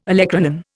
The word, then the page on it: olecranon: Indian